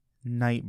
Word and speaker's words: Knight: And we call it Night Burger. K-N-I-G-H-T. – Oh, it fits burger. The Citadel theme. – But we never mention that there's a K there. – It's a silent K.